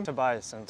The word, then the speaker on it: Tobiassen: So Alex Tobiassen is your last name? Tobiassen. Tobiassen, okay. Pronouncing a little bit too Germanesque.